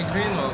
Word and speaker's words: Greenwald: É Greenwald, assim.